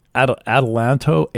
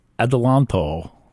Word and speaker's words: Adelanto: Adelanto, Adelanto. It's kind of like a combination of Adele and Atlanta and some Italian guy.